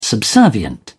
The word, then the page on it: subservient: British English